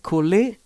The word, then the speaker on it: Kulli: My name is Kulli. How do you spell it? K-U-L-L-I.